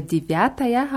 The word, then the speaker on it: девятая: Девять wird mit je ausgesprochen. Девять. Und bei Divyataya haben wir die Betonung auf Ja in der Mitte, deswegen wird Je am Anfang ja so ein bisschen wie I ausgesprochen. Nicht so ganz deutlich wie I, aber auch nicht wie Je. Also Divyataya.